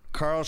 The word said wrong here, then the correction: Charl: Ch- Charl.